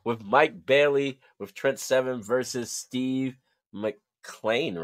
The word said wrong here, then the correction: Maclin: right? Is that how you pronounce it? – Maclin.